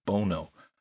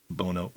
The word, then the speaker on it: Bono: Yes, Harris did pronounce the U2 singer's name as Bono, a la Sonny Bono of Sonny and Cher.